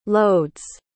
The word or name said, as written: loads